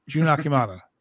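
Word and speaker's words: Jun Akiyama: You know, on Rampage, Rampage, on Collision, we had the American Dragon, Brian Danielson versus Junior Akimata. I can never say his name right. Jun Akimata. There it is.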